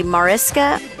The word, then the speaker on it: Mariska: Mariska.